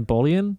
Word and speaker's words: Boleyn: so he could marry Anne Boleyn. I'm pronouncing yes. – that wrong. – Berlin. Anne Boleyn, I believe.